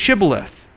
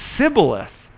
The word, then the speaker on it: Shibboleth: When he said, "No," they said to him, "Then say Shibboleth." And he said, "Sibboleth," because he couldn't pronounce it.